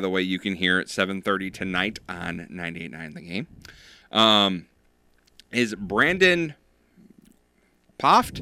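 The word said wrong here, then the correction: Pfaadt: No, the P is silent. – Oh. – Fod. Yeah.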